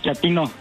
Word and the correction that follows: chatino: No, chatino. – Es como chato, ¿verdad? Chatino. – Sí, azunero, como chato, chatino.